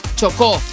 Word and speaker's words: Chocó: Chocó.